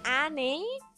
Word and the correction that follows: Anne: That's not Annie, that's spells like Anne and Frank.